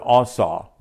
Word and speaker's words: Asa: So this king was one who did this, and his name is Asa, or pronounced in the Hebrew Asa, but it's spelled A-S-A.